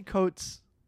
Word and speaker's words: Coates: Anyway, this is the latest album from Oliver Coates. So, well, maybe, would you say Coates? – How do you spell it? – C-O-A-T-E-S? – Yeah, Coates. – Yeah, Oliver Coates.